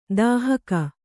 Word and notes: ದಾಹಕ: dāhaka

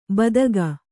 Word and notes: ಬೆದಕು: bedaku

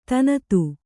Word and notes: ಧನದ: Kannada

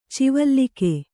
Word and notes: ಚಿವಲ್ಲಿಕೆ: civallike

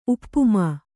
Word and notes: ಉಪ್ಪುಮಾ: uppamā

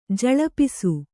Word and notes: ಝಳಪಿಸು: jhaḷapisu

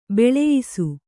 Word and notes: ಬೋಳಯಿಸು: bōḷayisu

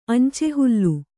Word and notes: ಅಂಚೆಹುಲ್ಲು: añcehullu

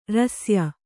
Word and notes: ಋಶ್ಯ: řśya

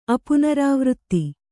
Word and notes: ಅಪುನರಾವೃತ್ತಿ: kannada

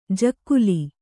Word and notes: ಜಗುಲಿ: Kannada

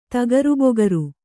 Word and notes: ತಗರಿಬಗರಿ: tagaribagari

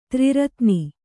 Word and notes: ತ್ರಿರತ್ನಿ: tri ratni

